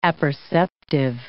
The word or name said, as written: apperceptive